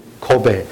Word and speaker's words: Kobe: The pronunciation a little bit different, but it's actually Kobe.